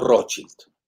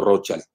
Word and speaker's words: Rothschild: Y, eh, ahí precisamente charlé con este general, le pregunté, ¿cómo se pronuncia? ¿Rothschild o Rothschild? Dicen alemán es que es el escudo rojo. Y hay algunos que en inglés dicen Rothschild, como si era child de niño, ¿no?